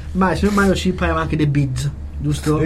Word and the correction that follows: bridge: The Bids, the altro sport. – Bridge. – Bridge. – Un po' difficile a pronunciare. – Ha dimenticato consonante qua e là.